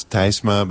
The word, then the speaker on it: Tysma: It's T-Y-S-M-A. So you would pronounce it in Dutch, you would pronounce Tysma, but in English it's usually Tysma.